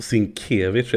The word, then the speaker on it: Sinkevich: Precis, Sinkevich är det alltså. Det kommer fan aldrig sätta sig. – Nej. Hon kommer heta Sinkevich. – Ja, det är, det är så man uttalar ord i Göteborg.